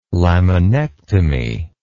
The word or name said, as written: laminectomy